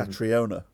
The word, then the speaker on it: Catriona: Katrina like mm-hmm. Catriona.